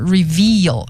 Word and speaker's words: reveal: 好，那么再来呢就是 reveal， R-E-V-E-A-L 这个单词的发音是 veal，这个 V 后边是长的 E，如果你发的时间不够长，听起来就像 review，就变成了 R-E-V-I-E-W。